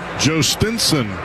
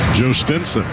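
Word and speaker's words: Jhostynxon: Here's Garcia, Joe Stinson Garcia. Not that that's how it's spelled, but that's how it's pronounced.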